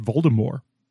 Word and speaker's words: Voldemort: Rowling that's supposed to be pronounced Voldemort.